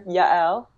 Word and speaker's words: Yael: So I just want to make it clear, Yael. Yeah, a lot of people say Yale. That's actually closer than some other tries I've heard.